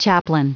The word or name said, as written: chaplain